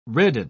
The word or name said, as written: redden